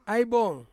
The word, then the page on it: ai bong: Ansus